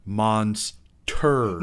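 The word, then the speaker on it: Monsturd: But I remember we are going to watch the classic Monstered. – Monstered? That doesn't sound that interesting. Perhaps you should pronounce it in a slightly different way. – Okay, we are watching the classic Monsterd.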